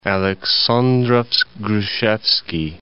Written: Aleksandrovsk-Grushevski

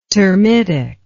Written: termitic

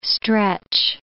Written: stretch

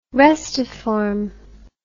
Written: restiform

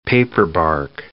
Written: paperbark

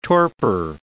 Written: torpor

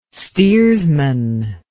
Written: steersman

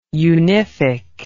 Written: unific

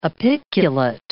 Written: apiculate

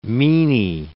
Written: meany

